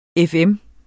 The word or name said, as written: FM